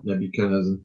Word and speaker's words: Nebuchadnezzar: O merchant, leave thy island, Nebuchadnezzar. Is that how you say it? – Nebuchadnezzar.